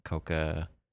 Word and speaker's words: Kouka: K O U K A Kouka. I guess yeah. is that right? Yeah, you just say the O for a little bit longer.